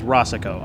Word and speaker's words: Racicot: Is my last name is Rossicot, R-A-C-I-C-O-T. And I'm not going to fart it out. – Sorry. – But silent T, right? – Right. – It's French. Canadian.